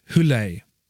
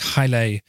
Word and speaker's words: hyle: Now, the Greek word for matter is hule, sometimes pronounced hyle with a Y.